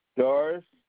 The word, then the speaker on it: Doris: Doris Fulgham. – You said Doris? – Yeah, D-O-R-I-S.